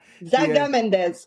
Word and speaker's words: Zaga Mendes: Voilà Alejandra Gaza Mendes. – Zaga Mendes. – Hein? Mes yeux commencent parce que je l'ai bien écrit, mais je le prononce mal.